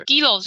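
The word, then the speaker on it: Gero: Giro 就是呃 Giro 拼 G E R O，Giro 其实就是夏吕的日文发音，然后也很接近就是这边的一个算是一个吉祥物青蛙的青蛙的那个叫声，所以它这个叫 Giro Giro Butter。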